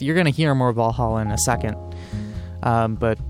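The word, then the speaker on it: VA-11 Hall-A: But you heard Welcome to Valhalla, that is spelled VA-11 space capital HALL dash capital A. Valhalla, because you got to make your bar name sound really fancy and cool, I guess.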